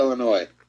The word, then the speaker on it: Illinois: Do you say Illinois. – Exactly. – No S on there.